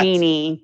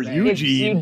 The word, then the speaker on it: Eugenie: It's pronounced Eugenie, which is, I guess, an English way of pronouncing that.